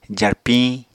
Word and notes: jarpiñ: apatani

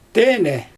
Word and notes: teenɛ: Xyzyl

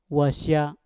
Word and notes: wasya: mpur

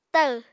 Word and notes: tahw: Apatani

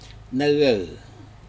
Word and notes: necec: Aneityum

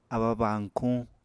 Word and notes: abba-bañkuñ: Apatani

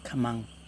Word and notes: kamaN: Yokoim

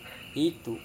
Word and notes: hitu: Serewen

